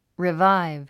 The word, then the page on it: revive: riváiv; リヴァイブ